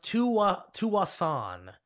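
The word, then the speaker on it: Tsawwassen: It's pronounced Tuwasan. – Tuwasan. – The S is silent.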